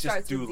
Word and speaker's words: doula: Or do you say a doula? How do you say that? Isn't it a doula? – Doula. – It's just doula. – With D, yeah. – It starts with D.